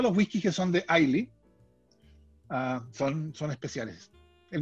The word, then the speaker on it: Islay: Entonces, hay, hay una isla que se llama Ailey, que se escribe Islay, en Escocia, donde hay siete destilerías.